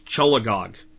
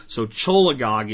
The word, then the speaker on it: cholagogue: It's cholagogue. C-H-O-L-A-G-O-G-U-E, cholagogue. What is cholagogue? Cholagogue means it promotes the discharge and flow of bile from the gallbladder. And gog, G-O-G-U-E, actually means flow. So cholagogue is increasing the flow of bile from the gallbladder.